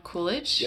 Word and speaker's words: Coolidge: Calvin Coolidge.